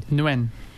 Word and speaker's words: Nguyen: How do you pronounce N-G-U-Y-E-N? – Nguyen. – How's it go? – Nguyen. – Okay, we were all wrong.